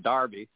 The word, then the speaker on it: Derby: I'm talking about English minstrel song called Darby Ram or Derby Ram, but that's how we pronounce it. But in England, they probably pronounce it Darby, and it was about a giant sheep.